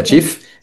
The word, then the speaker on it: Cheaf: No tiene costo, se escribe Cheaf, pero se pronuncia Chief y se Sí.